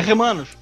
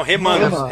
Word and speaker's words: Remanos: Os assim, Remulanos cara. a gente viu facas, mas nos Remulanos, né? – Não, é, é, é, Remanos. Não, Remanos, Remanos. – Remanos. – É, eu falei errado, Remanos.